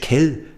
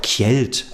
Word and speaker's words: Kjeld: Man muss ja jetzt nicht auf Dänisch Kell sagen. Bei uns hieß er ja auch Kjeld, also so wie man ihn schreibt, wird ja im Dänischen ein bisschen anders ausgesprochen, aber die haben dann gleich Karlchen draus gemacht.